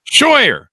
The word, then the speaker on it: Scheuer: Scheuer.